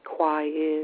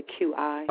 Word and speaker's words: Qi: Could you explain what the QI is, if I'm pronouncing that correctly, the QI? – Oh, actually, that's pronounced Qi.